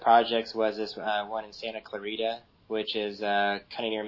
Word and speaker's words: Santa Clarita: Yeah, Santa Clarita.